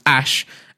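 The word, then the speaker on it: Æ: I mean, it's just X, the letter X, and the AE is pronounced Ash.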